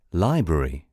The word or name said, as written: library